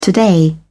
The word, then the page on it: today: British English